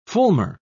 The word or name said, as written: fulmar